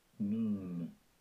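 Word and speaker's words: Noon (נון): Noon. Noon. – The narrator said, Strong's H, standing for Hebrew. This is the Hebrew word, Hebrew side. Number 5126, pronunciation noon.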